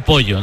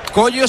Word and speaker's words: Kojo: Es Collo, no Pollo, ¿no? – Collo se dice, pero se escribe Cojo. K-O-J-O.